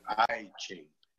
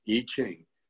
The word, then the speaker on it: I Ching: I Ching, it looks like I Ching, but the I is pronounced E, I Ching.